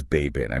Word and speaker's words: Baybayin: So for listeners who might not know, what exactly is Baybayin? I mean, how do you even spell that? – It's B-A-Y-B-A-Y-I-N.